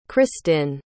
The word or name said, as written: kristin